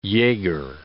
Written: jaeger

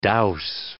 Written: douse

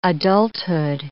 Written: adulthood

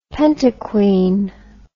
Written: pentaquine

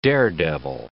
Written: daredevil

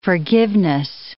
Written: forgiveness

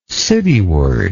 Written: cityward

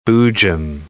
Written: boojum

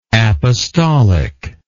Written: apostolicalness

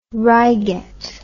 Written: reigate

